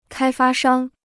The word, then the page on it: 开发商: kāi fā shāng